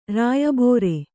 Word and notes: ರಾಯಬೋರೆ: rāya bōre

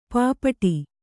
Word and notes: ಪೈಪಾಡು: Kannada

